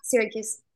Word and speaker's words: Syracuse: Matthew Begerman, tight tackle from, is it Syracuse? Is that how you pronounce it? – Yeah, Syracuse.